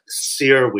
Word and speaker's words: Cyr: I was just like, Sear Wheel. And it's spelled C-Y-R, because it's named after somebody, a circus artist named, I believe his first name is Daniel Sear.